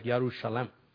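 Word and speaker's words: Yerushalem (ירושלים): In the Hebrew language, I may not pronounce it properly, but Jerusalem sounds more like Yerushalem.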